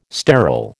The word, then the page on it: sterol: American English